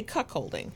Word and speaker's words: cuckolding: I always say cuckolding. Am I saying that right? – That's how I would say it.